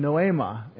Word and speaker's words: noema: What's the word for mind? Noema. N-O-A-M-A. N-O-E, I'm sorry. N-O long E-M-A.